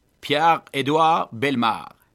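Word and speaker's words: Pierre-Édouard Bellemare: Pierre-Edouard Belmar.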